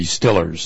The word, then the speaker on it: Steelers: Sorry, those are the Stillers. – The Stillers, correct. If you're saying it right, yeah.